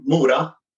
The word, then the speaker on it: Mora: One of the things I saw you posted once was that you were actually born in Mora, the town in Sweden. I don't know if I'm saying that correctly. How do I pronounce it? – It's okay. Mora.